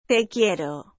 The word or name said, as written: te quiero